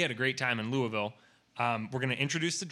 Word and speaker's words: Louisville: I learned that is officially how you pronounce it. Our friend Pico says you have to pronounce it like you have gravel in your mouth, Louisville. Whoa.